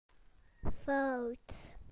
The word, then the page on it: wrong: British English